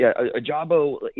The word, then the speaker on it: Ojabo: David, Ojabo, he's... – Oh, it's a hard J, my bad.